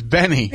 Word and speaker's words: BENEE: I know you're not ready yet. It's Benny. So, can you stop making noise with the mic? So, Benny who sings that song, Super Lonely, it's spelled B-E-N-E-E. – Yeah. So with Billy, because when we produce the countdown and stuff, like, you know, well, I'll write it up or whatever, I have to spell it Benny because it's pronounced Benny, B-E-N-N-Y.